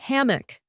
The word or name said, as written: hammock